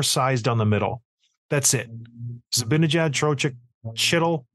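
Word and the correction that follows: Chytil: It's not Chittle. You know why I say Chittle? Because there's a principal at my kid's school is Chittle. So I see it. They spell it the same way. That's why. So don't. I know I pronounce names a little bit off. That was Heedle.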